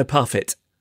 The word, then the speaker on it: Parfitt: Parfait? – No, Parfitt. – Parfitt.